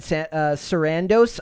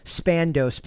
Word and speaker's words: Sarandos: So this was an interview with Maureen Dowd, which is, his name is Ted Sarandos. I can never pronounce his name. I just want to say Spandos, but that's not what his name is.